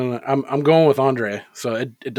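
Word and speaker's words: Andrzej Żuławski: But I, I went on to see how you actually pronounce this guy's first name because there's Z's and J's, but it's just Andre. Do I have that right? It's Andre Zulawski.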